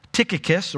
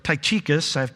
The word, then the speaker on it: Tychicus: They're both delivered by a member of Paul's ministry team called Tychicus or Tychicus. People have debated how it's supposed to be pronounced.